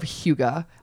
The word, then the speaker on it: hygge: It's spelled H-Y-G-G-E. – Okay. – Hygge or Hygge. – Hygge. – Hygge.